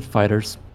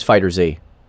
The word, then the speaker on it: FighterZ: People pronounce it FighterZ, because that uppercase Z is dumb. – Oh, okay, we are talking about FighterZ. The actual proper name is FighterZ.